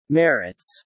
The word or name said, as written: merits